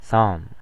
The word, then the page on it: psalm: American English